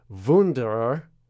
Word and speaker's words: Wunderer: It's directed by Daniel Wunderer, or if you want to use the the Austrian pronunciation or the German pronunciation. It's Wunderer.